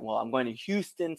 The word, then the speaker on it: Houston: Is that how you say it? Houston? – Yeah, it's Houston.